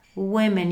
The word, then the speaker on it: women: A představte si ty měkký i ty štíhlý ženy vedle sebe, takže výslovnost, když je hodně žen, women, women. Jedna woman, woman a hodně women.